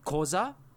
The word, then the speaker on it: Xhosa: Like, one of the languages in South Africa is called Xhosa, but it's spelled with an X-H-O-S-A. So usually that's combined with a clicking noise. – I can't do that. – Like, some people would say Xhosa, but I've heard it pronounced as Xhosa.